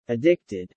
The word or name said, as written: addicted